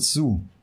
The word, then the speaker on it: siu: S-I-U. – S-I-U, C-U, C-U, das aus dem Englischen ins Deutsche. Nein? Oh S-I-U, äh, ausgesprochen wird Su. Su. Su. Aha. Mhm. Also mit sehr viel U.